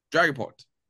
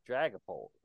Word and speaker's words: Dragapult: Dragapult. There we go, Dragapult. – Dragapult, okay.